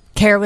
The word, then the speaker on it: Kara: I usually mess up a lot of our experts' names, but Kara with a K, but it's pronounced with a C. – Kara with a K.